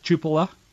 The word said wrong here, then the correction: Chalupa: They, this morning, a little while ago, about an hour ago, they moved that Alexandra uh, Chalupa, that's the way you pronounce it, Chalupa, uh, that her name be removed, redacted, because they didn't want anybody to know who she was.